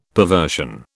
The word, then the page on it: perversion: British English